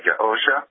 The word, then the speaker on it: Yahusha: First of all, just on the pronunciation of Yahusha, I would have a tendency to separate the ah and the O sound and get the hard O in there, like Yahusha.